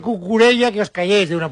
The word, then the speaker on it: Cucurella: Bueno, Cucurella.